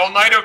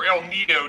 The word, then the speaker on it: El Nido: Yeah, El Nido, California, or El Nido, depending on who you ask.